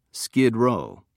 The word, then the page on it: skid row: British English